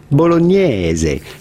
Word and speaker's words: bolognese: In French, we would say spaghetti bolognese. And in English, it's normally say spaghetti bolognese or something like that. But the the typical Italian pronunciation would normally be spaghetti bolognese.